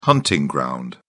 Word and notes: hunting ground: British English

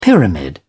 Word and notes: pyramid: British English

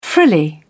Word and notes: frilly: British English

